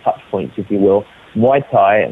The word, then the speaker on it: Muay Thai: Now, before we go to the break, could you explain to me what a Muay Thai match is? – Yeah, sure.